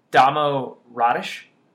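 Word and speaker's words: Damjan Rudež: He pointed out on Basketball Reference, it's listed that the correct way to pronounce it is Damo Radish, I believe.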